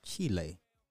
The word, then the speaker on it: Chile: I thought that it was called Chile. Why y'all calling it Chile? It's Chile, right? I mean, my my Espanol is is horrible, but I know it's pronounced Chile.